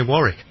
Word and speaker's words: Warwick: We say, of course, over on the other side of the pond, we say Warwick. Dionne Warwick. Because we do have a town which is spelled exactly the same, W-A-R-W-I-C-K, and we call it Warwick.